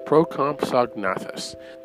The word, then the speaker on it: Procompsognathus: But every time I try to pronounce it, I get tongue-tied. So they're called... They're commonly called Compies. But they're Procompsognathus. Procompsognathus. That's the best way.